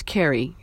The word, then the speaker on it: Carey: My first name is spelled E-L-E-A, and my last name is Carey, C-A-R-E-Y.